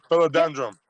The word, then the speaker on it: Philodendron: Philodendron.